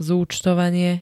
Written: zuctovanie

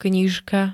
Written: knižka

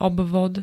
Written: obvod

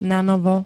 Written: nanovo

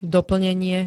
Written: doplnenie

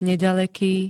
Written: nedaleky

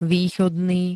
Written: východný

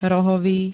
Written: rohovy

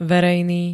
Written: verejný

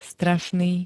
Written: strašný

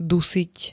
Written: dusiť

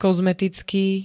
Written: kozmetický

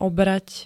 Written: obrať

